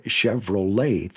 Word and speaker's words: Chevrolet: There is no A in Chevrolet, and there is a row. Now, I'll admit, the T at the end kind of throws you off. L-E-T is pronounced lay.